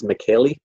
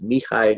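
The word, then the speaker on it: Mihály: Yeah, no, it, it, it's it's spelled like it's McKaylee, but it's Mihai, and his last name is Csikszentmihalyi.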